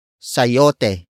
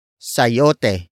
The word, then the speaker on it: sayote: Sayote. Sayote. Sayote is spelled as S-A-Y-O-T-E.